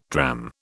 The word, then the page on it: drachm: British English